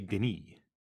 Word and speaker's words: Denis: In French, you say Denis. Right? That's like how you would pronounce Dennis.